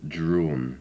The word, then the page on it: drawn: English